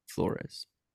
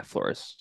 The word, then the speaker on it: Flores: Adding to their coaching staff, a defensive coordinator, Mr. Brian Flores. – Brian Dable? Yeah, Flores. Yeah, you're right. – Yeah, send him the wrong message. – You're putting the wrong emphasis on the wrong syllable.